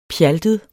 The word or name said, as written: pjaltet